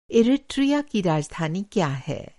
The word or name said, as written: इरिट्रिया